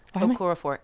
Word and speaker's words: Okorafor: Okorafor. It's Okorafor. It's just like it's spelled.